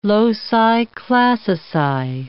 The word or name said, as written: loci classici